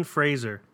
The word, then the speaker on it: Fraser: Brendan Fraser, however he actually pronounced it. – 6 minutes standing of ovation, Fraser. – Mm-hmm. – Fraser is how he pronounces it.